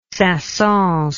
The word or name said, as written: saint saens